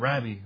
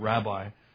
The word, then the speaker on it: rabbi: Rabbi, Rabbi. I've always pronounced that word rabbi, but it's actually supposed to be pronounced Rabbi.